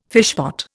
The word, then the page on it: fishpot: Indian